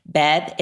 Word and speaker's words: BEB: BEB, is it BEB? Am I saying it right? Right? – B-E-B. It's an acronym. – B-E-B.